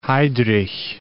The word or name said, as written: Heydrich